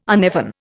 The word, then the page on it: uneven: Indian